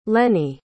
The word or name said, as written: lennie